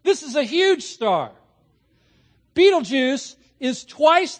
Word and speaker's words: Betelgeuse: It is called Betelgeuse or Betelgeuse or Betelgeuse. Pick your pronunciation. I'll choose Betelgeuse just because it's cool.